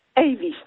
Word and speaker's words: Avis: Czy pani nazwisko wymawia się Avis, tak? – Avis, tak.